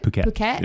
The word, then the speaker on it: Phuket: Phuket.